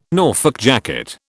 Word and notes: Norfolk jacket: British English

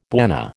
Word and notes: bwana: American English

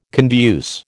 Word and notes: conduce: American English

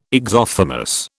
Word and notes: exophthalmos: British English

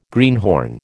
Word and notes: greenhorn: American English

